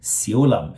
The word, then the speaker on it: Siulam: Now, usually in America, when we say Shaolin, which is from the Mandarin pronunciation, but in Cantonese, it would be a little bit different. It would be Siulam, and the temple is Ji, right?